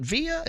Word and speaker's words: VIA: My guest is Ray Zielinski, vision education specialist with VIA, V-I-A, VIA. Is it VIA? Is that how we pronounce it? – It is. – Okay, it's VIA.